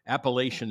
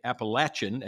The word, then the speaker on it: Appalachian: He grew up in the mountains where he experienced the wonder of Appalachian storytelling, or maybe I should say Appalachian. I guess it depends on what part of the country you're in as to how you pronounce that, but I think both are correct.